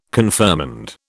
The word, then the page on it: confirmand: British English